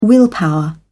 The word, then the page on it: willpower: British English